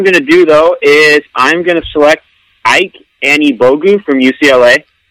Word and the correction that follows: Ike Anibogu: Wait, start over. It's EK, we learned. – Seriously? – Oh, shoot. EK Anibogu? – Yep.